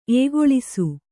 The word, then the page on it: ಏಗೊಳಿಸು: ēgoḷisu; Kannada